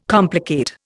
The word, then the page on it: complicate: Indian